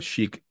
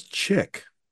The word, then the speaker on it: Chic: It was spelled chick, which confused the heck out of me. Once again, as a 9-year-old, it's like, Mom, that says chick. It's pronounced chic, baby. But it says chick.